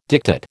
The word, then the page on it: diktat: American English